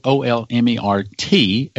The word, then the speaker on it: Olmert: Or is that spelled O L M E R T as in Ehud Olmert, pronounced the same way, which used to be the prime minister of Israel?